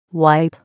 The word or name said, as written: wipe